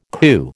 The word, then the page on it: coup: American English